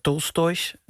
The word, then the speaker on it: Tolstoj: Ja, we hadden het aan het begin van onze uitzending begon je met een stukje uit een boek van schrijver Tolstoj. Zo zeg ik het goed, ja.